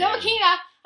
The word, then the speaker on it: Ntilikina: Ntilikina!